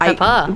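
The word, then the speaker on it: Pepa: He was like a background guest star type thing in Salt and Pepper's, or Peppa. It's spelled like that, but Peppa.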